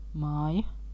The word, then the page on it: maison: /mɛ.zɔ̃/